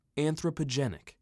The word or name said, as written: Anthropogenic